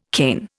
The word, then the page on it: Cain: Indian